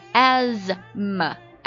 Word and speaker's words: asthma: 他说 pollution can aggravate asthma，污染会使这个哮喘加重。 asthma，闭上眼睛听我读一下这个哮喘这个单词啊， asthma asthma，这个 th 感觉好像只是摆设没有发音呢、啊、哮喘气喘的意思。